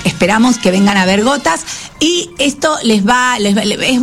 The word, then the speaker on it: Ghotazz: Gotas, que se llama así, Gotas. ¿Cómo se escribe el grupo? – Se escribe G-H-O-T-A-Z-Z. – Claro, como repito, si G-H-O-T-A-Z-Z.